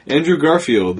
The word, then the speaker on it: Andrew Garfield: Andrew Garfield.